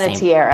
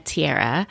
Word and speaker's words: Panettiere: Panettiere. – Name. Panettiere.